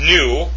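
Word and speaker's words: nu: It's pronounced new.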